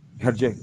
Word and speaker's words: Hergé: Jerge. Hergé, gracias, Hergé. Gracias por, la, por el francés ahí.